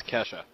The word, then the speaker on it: Kajsa: Swedish blonde bombshell, K-A-G-S-A. – Kesha? – Kesha? – Keisha? – Something like that. K-A-J-S-A. – She can say her name as much as she wants. I doubt I'd remember it. – Kesha. – Going with Kesha. – It's Kesha.